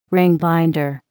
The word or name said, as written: ring binder